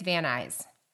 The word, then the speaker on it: Van Nuys: N-U-Y-S apparently is eyes. And I can't wait till Roseanne from California Dreaming Pod hears us pronounce this. Please tell us we're doing it right, but I think it's Van Nuys.